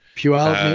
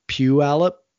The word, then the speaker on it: Puyallup: He pronounces it more like Puyallup, like Puyallup, kind of like that, instead of Puyallup, which is kind of how everyone else pronounces it. So apparently that's way he pronounces is kind of an old way of pronouncing it that kind of old timey Puyallup people say.